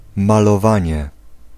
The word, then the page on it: livery: [ˈlɪv.ə.ɹi]; /ˈlɪv.ɹɪ/; /ˈlɪv.ə.ɹɪ/